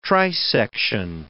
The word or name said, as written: trisection